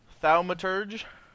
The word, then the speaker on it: Thaumaturge: It's Thaumaturge.